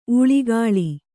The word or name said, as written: ಊಳಿಗಾಳಿ